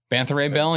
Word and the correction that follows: Beta Ray Bill: Beta Ray Bill, I always say his name wrong.